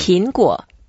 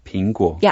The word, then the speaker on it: 苹果: They will say, oh, are you talking about the fruit or the electronic devices or the company, 苹果? – Yeah. So you have to distinguish between the... – You have, but let's learn how to say it first. – Great. – 苹果。 – 苹果。